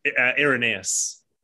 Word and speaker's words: Irenaeus: Irenaeus. I would say... – You pronounce it Irenaeus.